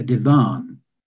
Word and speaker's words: divan: And we called our large chair a divan with a slight stress on the last syllable.